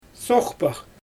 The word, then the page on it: sooxpax: Xyzyl